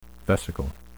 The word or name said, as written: vesicle